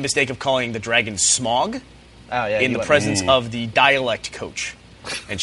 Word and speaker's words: Smaug: No, it's Smaug is the name of the dragon. It's very important to pronounce it correctly.